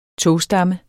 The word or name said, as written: togstamme